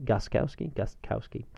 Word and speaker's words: Gostkowski: Gostkowski, because you have to pronounce the T, I think.